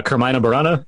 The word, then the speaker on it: Carmina Burana: um, a movie I watched, and this, this links to Excalibur, uh, in Excalibur, you you have um, uh, Carmina Burana.